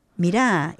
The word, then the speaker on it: mirá: Mirá, y ponen el acento en las palabras donde a mí siempre yo reclamaba, pero al final es la idiosincrasia de ellos, porque decía, no, es que no es así gramaticalmente, no dice mirá, es mira.